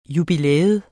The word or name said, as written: jubilæum